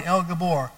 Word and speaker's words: El Gibor: El is pronounced phonetically L-E-L. Give, G-H-I-B, Bor, B-O-R-E, El Gabor.